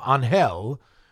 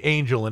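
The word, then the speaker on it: Ángel: And it follows the life of Angel, which looks like angel in English, but in Spanish it's pronounced Angel, who works in an ambulance service.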